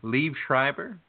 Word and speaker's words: Liev Schreiber: Liev Schreiber? Is that how you say his name? Liev yeah. – Schreiber? Yeah.